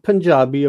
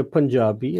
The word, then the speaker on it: Punjabi: Do we say Punjabi? So that's incorrect, right? – No, Punjabi or Punjabi is fine.